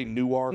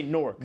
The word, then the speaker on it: Newark: The same way, like, we don't say Newark. – No, we say Nork. – Nork. – N-O-R-K.